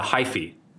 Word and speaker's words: hyphae: Phyllae, is that how you pronounce it? – I'm trying to think. – The hyphae.